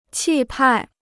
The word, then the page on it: 气派: qì pài